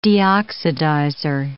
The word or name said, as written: deoxidizer